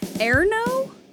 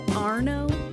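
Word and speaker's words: Ernő: Erno? – Yes. – I got that one. – Is it Arno? Or how do you spell it? – E-R-N-O with smatterings of dots.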